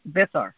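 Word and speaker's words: Abiathar: How do you say his name? Oh, Abithar. Abithar. – Yeah. – Abithar. It's Abithar.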